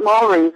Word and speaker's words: Maori: They're not Maoris, they're Maoris.